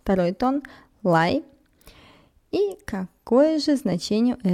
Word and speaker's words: 来: На очереди у нас интересный глагол «лай», «лай», вторым тоном «лай», Давайте рассмотрим сначала его произношение, а впоследствии уже его перевод и использование. Лай произносится вторым тоном, то есть мы коротко спрашиваем, переспрашиваем «да», «лай», «лай».